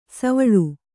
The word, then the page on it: ಸೋವಳಿ: Kannada